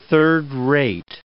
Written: third-rate

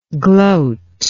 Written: gloatingly

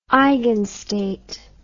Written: eigenstate

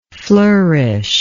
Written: flourisher

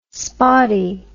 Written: spottie

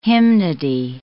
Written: hymnody

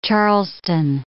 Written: Charleston

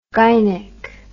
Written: gynec

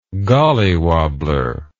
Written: gollywobbler

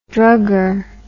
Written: drugger

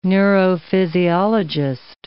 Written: neurophysiologist

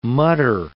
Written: mutter

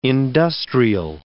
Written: industrial

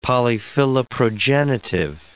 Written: polyphiloprogenitive

